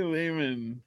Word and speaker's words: Lehman: Ari Lehman rhymes with demon.